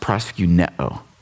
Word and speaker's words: proskuneo: The Greek word translated worship is pronounced proskuneo in this passage.